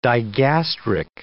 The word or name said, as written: digastric